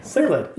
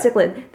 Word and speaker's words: cichlid: Oh, I don't know how to pronounce it. – Cichlid. – Yeah, cichlid. Thank you.